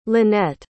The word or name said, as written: linette